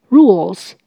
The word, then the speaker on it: Google: 请留意 Google 这个字的发音，第二个音节 G L E L 舌头要稍微抬高。 Google，Google，Rules Google，规则的复数形，在这里就是指麻将的游戏规则。